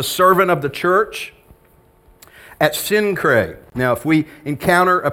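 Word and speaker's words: Cenchreae: And you can see that Synchrae, which the Greeks pronounce it with a hard See, Kinkrae is how they say it.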